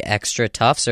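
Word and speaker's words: XtraTuf: Is that spelled like extra tufts, or does it have any sort of special spelling? – It does have a special okay.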